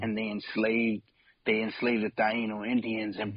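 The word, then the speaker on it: Taíno: And I think if I said it right, you're saying Taino, right? – Yeah, Taino Indians.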